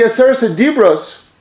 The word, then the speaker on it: Aseret HaDibros: the Aseret HaDibros, that's how you say it in Hebrew, we translate it as the 10 commandments.